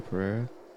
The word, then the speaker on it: Pereira: Oh, Pereira.